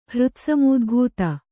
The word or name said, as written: ಹೃತ್ಸಮೂದ್ಭೂತ